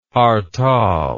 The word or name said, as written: hartal